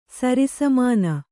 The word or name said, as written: ಸರಿಸಮಾನ